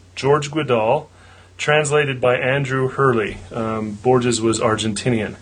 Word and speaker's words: Borges: Last name is Borges. Is that right? – Borges? – Oh, yeah.